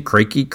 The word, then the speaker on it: Crake: He's like, so that was Crike. How do you say his name? Crake.